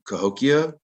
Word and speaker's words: Cahokia: And it was traded all the way up to Cahokia. I think I'm pronouncing that correctly. – Yep.